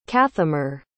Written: cathmor

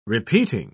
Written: repeating

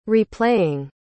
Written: replaying

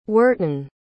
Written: worton

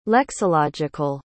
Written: lexological

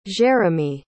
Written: jeremie

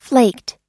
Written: flaked

